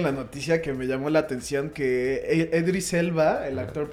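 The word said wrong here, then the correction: Idris Elba: Idris Elba.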